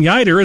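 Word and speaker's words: Yeutter: Trade Representative and Secretary of Agriculture Clayton Yeider is being released. Rhymes with fighter, Clayton Yeider, American Statesman is the name of it.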